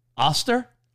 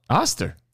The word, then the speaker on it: Oster: You could get an air fryer for like $100 or so, like an Oyster one. Oster. – You call it oyster? – Well, it's spelled O-Y-S-T-E-R, right? – No. – What is it spelled? How's it spelled? – Oster. – O-S-T-E-R? – You put a Y in there.